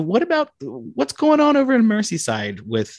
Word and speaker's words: Merseyside: So my biggest likelihood of percentage comes from, I want to say Merseyside. Does that, did I say that right? – Is it Merseyside? Yes. – Yes.